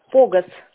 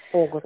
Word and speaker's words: Погос: Да, его Погос зовут на первое О ударение. – Как? – Погос.